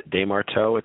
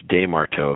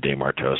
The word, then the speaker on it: DesMarteau: Yeah, DesMarteau, it's a French-Canadian name, so if you use a Parisian pronunciation, it's DesMarteau. If you use more Quebecois or Montreal-based, it would be DesMarteau. So just depends on who you ask, but my parents always said DesMarteau, so I followed suit.